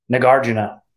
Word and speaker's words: Nagarjuna: You know, is Nagarjuna really a guy, if I'm even pronouncing his name, Nagarjuna.